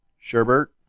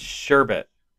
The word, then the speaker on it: sherbet: Sherbert. Yeah, there is no R. It's sherbet.